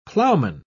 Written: plowman

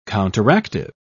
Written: counteractive

